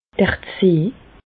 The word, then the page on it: d'r Zee: Bas-Rhin; Reichshoffen